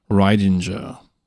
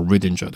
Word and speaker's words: Ridinger: Modern recreation of the Liverpool fuzz tone, which was the first fuzz created by Fox Pedals founder and current Dan Electro owner Steve Ridinger, or Ridinger, depending on how you want to pronounce it.